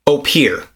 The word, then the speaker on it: Opihr: Opir, but it's spelled O-P-I-H-R.